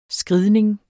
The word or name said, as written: skridning